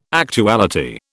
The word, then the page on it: actuality: British English